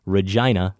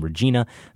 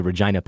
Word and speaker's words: Regina: Regina, Saskatchewan. The name of that city isn't pronounced like the woman's name, Regina. The I is pronounced I, not E. A helpful pronunciation guide. It rhymes with vagina.